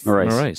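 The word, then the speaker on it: Marise: while Acting Defence Minister Maurice How do we say her name? – Payne. – Yeah, no, the first name. – Maurice. – That's right. Yeah, you got it right.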